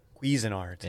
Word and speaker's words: Cuisinart: Is that how you say it? Cuisin? – I say quiz. – Quiz? – Quiz. – Mine's the Cuisinart one. – Cuisinart.